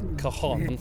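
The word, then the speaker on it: cajón: I've got to pronounce it correctly. – Cajon. – Thank goodness I said it correctly.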